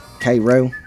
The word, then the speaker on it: Cairo: They pronounce Cairo. Cairo. That's where the Cairo corn syrup comes from, Cairo, Georgia.